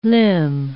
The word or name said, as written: limb